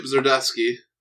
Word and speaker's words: Zdarsky: I was going to say Stillwater by Chip Zdarsky. I think that's how you pronounce the last name.